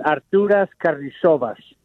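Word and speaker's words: Artūras Karnišovas: But anyways, his name is pronounced Arturas Carnishovas.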